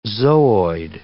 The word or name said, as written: zooid